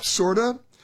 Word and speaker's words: Corda: It's called Pop Sorta. It's spelled C-O-R-D-A, but pronounced with like an S, sorta.